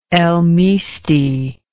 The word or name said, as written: El Misti